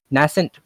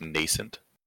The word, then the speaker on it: nascent: The bizarre death of a woman who gave birth to a rapidly aging baby after an hours-long pregnancy challenges the nascent partnership between Dr. Bishop, Peter, and Olivia, who reaches out to Massive Dynamics, Nina Sharp, for answers. – I believe you'll find that's pronounced nascent.